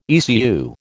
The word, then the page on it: ECU: American English